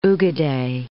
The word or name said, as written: Ugedei